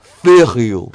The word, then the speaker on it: Verheul: It's spelled J-E-R-O-E-N. Right? But it's pronounced Yeren. So V-E-R-H-E-U-L pronounced Yeren. And then the surname V-E-R-H-E-U-L is pronounced Verheul. Verheul. Verheul. So the V is F and the J is Y.